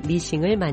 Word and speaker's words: 미싱: 기계 제작에 관심을 돌렸고 1850년 페달을 밟아 작동하는 바늘이 위아래로 움직이는 구조의 재봉틀 싱어 미싱을 만드는 데 성공합니다. 1920년대 한국에서 일본식 발음으로 머신을 미싱으로 발음하며 싱어 미싱으로 알려졌습니다.